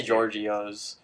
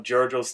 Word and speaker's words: Giorgio's: It has an I in it, which is throwing me off. – It's spelled G-I-O-R-G-I-O's. – I want to say Giorgio's.